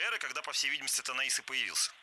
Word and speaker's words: Танаис: Да, это действительно первый город Танаис. Я буду говорить именно так. В принципе, можно и Танаис, это такая русская традиция произношения. Но если по-гречески, это всегда Танаис.